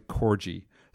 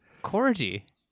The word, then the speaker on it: corgi: Corgi? – Corgi, sorry. Stood guard, barking if Sandy strayed from the blanket. That's a bad mispronunciation. Shit. – All-time bad one. – I'm going to hear about that. I'm not a dog person. I've seen the word a million times. Never heard it out loud, I guess.